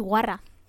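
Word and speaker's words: guarra: it's guara. – Guara? – Yeah. – There you go. – You've got to roll your R. – Warra!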